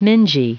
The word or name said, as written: mingy